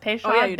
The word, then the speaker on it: Peychaud: And if you want to go the New Orleans traditional way, and I may say this totally wrong, Peychaud bitter or Peychaud bitters. Peychaud, I said it right? – Peychaud. Yep. – Peychaud bitters.